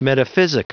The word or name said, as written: metaphysic